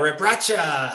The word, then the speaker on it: Rebraca: Like I said, traded Chucky Atkins, Bobby Sura, Rabraka. Is that how you pronounce his last name? Zeljko, Rebraca.